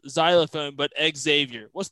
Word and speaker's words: Xavier: And he's like, it's pronounced Xavier, like xylophone.